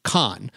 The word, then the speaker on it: Kahan: Paul, who, by the way, his name is spelled Kahan, K-A-H-A-N, but the second A is actually silent. He's long since stopped correcting people, but his name is actually pronounced Kahn.